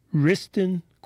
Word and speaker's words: Wriston: And one of the things we used to do, always looking for great music, was go up to Riston Quadrangle. Riston Quadrangle. That's spelled W-R-I-S-T-O-N.